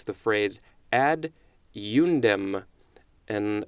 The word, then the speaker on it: ad eundem: So it's two words. Ad is the first word, and then eundem is the second word. Ad eundem. That is what the pronunciation is telling me.